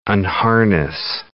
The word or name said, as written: unharness